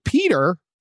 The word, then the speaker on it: Peadar: This is how Irish it is. It's spelled P-E-A-D-A-R. – Oh, all right. – Right? That is Irish. That's very Gaelic. Peter Doyle, 66, and he has a nephew, Declan Haughey, or Haughey.